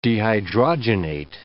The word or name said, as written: dehydrogenate